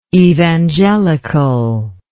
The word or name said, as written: evangelicality